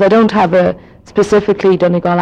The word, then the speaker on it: Donegal: and Glenties would be pronounced Glenties because it's G-L-E-N-T-I-E-S, and it's Donegal, not Donegal, but that's okay. You can call it Donegal if you want.